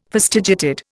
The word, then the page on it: fastigiated: Indian